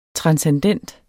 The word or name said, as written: transcendent